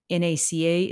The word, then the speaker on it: NACA: I don't know, and I don't think anyone knows why NACA is pronounced with the letters and NASA is pronounced as a word.